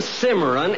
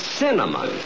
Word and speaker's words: cinnamon: Some donuts, some chocolate cake, some pastry, and a half a dozen Cimarron rolls. – You still can't pronounce it, can you? Look, it isn't Cimarron, it's cinnamon.